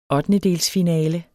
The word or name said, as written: ottendedelsfinale